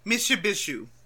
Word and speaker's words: Mishipeshu: How do you say it again? – Mishipeshu.